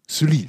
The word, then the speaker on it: Sully: Ist der, jetzt wird es nochmal schwer von der Aussprache, Jardin de l'Hôtel Sullier. Ja? Also ein Garten, Garten des Hotels Sully. Sully, so sagt man.